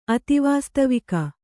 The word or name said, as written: ಅತಿವಾಸ್ತವಿಕ